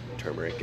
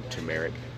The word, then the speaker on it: turmeric: she made me this tea out of, I think it was turmeric and or turmeric. How do you say it? Turmeric? Turmeric? This has been bothering me. – See, I learned the right way. There's either a first R or not.